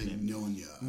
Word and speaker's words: Mignogna: I can't pronounce his Mignogna. – Name. Mignogna.